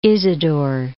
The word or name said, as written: Isidore of Seville